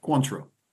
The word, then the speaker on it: Cointreau: You get 1/2 ounce of triple sec or Cointreau. Is that how you say that? – Cointreau.